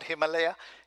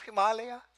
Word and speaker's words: Himalaya: But if you want to pronounce it right, it is not Himalaya, it is Himalaya. Because it has a meaning. It changes. The meaning changes when you shorten the A.